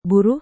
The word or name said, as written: buruh